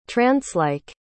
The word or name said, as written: trancelike